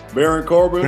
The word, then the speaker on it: Baron Corbin: How you say his name? Barone Corbin. Baron Corbin. – Baron Corbin. – Uh, yeah, yeah. Baron Corbin.